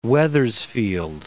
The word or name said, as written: Wethersfield